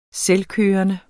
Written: selvkørende

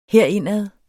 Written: herindad

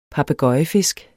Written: papegøjefisk

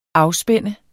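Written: afspænde

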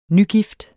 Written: nygift

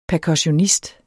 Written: percussionist

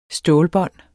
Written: stålbånd

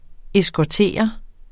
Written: eskortere

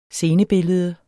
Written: scenebillede

